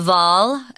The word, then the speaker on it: Waal: It starts out by saying, crisscrossed by the Rhine, the Waal, it's pronounced with a V sound and not a W, and the Meuse rivers, the Netherlands is prone to flooding.